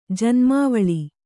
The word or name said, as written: ಜನ್ಮಾವಳಿ